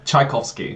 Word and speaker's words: Tchaikovsky: And yeah, so he's glad he's stepped in to take his place and talk about Children of Time by Adrian Tarkovsky. Tarkovsky, am I saying that right? – Tchaikovsky.